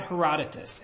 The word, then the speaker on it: Herodotus: A note about Herodotus.